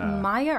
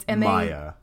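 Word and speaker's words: Maja: Is it Maya or Ma? It's Maya. – It's M-A-J-A. – But it's Maya. – It's Maya. Okay.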